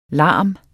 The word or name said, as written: larm